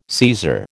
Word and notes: Caesar: American English

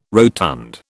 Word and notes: rotund: British English

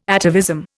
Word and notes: atavism: Indian

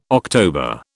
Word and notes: October: British English

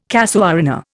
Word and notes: casuarina: Indian

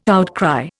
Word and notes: outcry: Indian

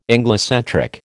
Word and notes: Anglocentric: American English

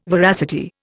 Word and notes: veracity: Indian

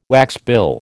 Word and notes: waxbill: American English

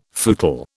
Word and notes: footle: British English

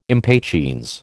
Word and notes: impatiens: American English

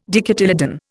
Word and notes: dicotyledon: Indian

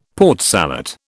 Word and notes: Port Salut: British English

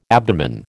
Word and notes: abdomen: American English

